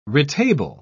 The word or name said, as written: retable